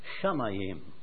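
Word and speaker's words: Shamayim (שמים): Ich habe das Wort Erde, hebräisch, gelesen, ausgesprochen, Eretz, und Himmel, Shamayim.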